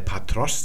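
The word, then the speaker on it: Patros (πατρός): Und das sehen wir hier bei Patros. Das ist die Genitivform des Vaters. Da seht ihr, da springt der Akzent von dieser zweiten Silbe auf die Endung denn die Genitivendung os ist im Griechischen immer betont.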